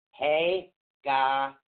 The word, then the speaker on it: Hagalaz: So it's actually, it's Hagalis, excuse me, Hagalaz. – Hagalaz. No, right. It's actually spelled H-A-G-A-L-A-Z, but it's pronounced H-A-G-A-W-L-A-W-S.